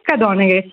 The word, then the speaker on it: Cadoneghe: Qual è la pronuncia corretta? Ciao, ben arrivata. – Ciao, ciao Giulia, ciao a tutti e a tutti. La pronuncia corretta è Cadoneghe, sì. Cadoneghe, provincia di Padova.